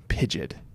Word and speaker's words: Pidgeot: Pidgeot is Joshy's sweetie. – Pidgeot. Pidget. Pidget. It sounds like idiot.